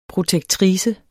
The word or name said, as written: protektrice